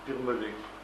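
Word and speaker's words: Birmelé: Comment ? Birmelet. C'est comme ça se prononce. André Birmelet.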